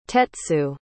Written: tetsu

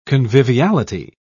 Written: conviviality

